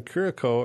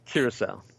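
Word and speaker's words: Curaçao: So I'm looking at Google Earth and Curacao, or how do you say it's spelled? Curaco, but you pronounce it Curacao. – Curacao, like there's an L on the end, huh?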